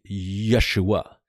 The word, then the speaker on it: Yeshua: Remember the J in Hebrew, you pronounce as a Y. So if you speak English mostly, it's not Jacob, but it's Yaakov. It's not Joseph, but it's Yosef. And then Jesus, of course, would be Yeshua.